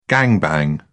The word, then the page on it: gang bang: British English